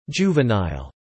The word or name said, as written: juvenile